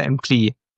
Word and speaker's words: Employ: Employ oder Employee? – Employ heißt es ja. Ungefähr wie Employee und dann nimmst du die O weg und dann Employ.